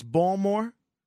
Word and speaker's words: Baltimore: Anyway, if you were from Baltimore, you would pronounce it ball as in ball player, more, and you'd delete the T and the L in between.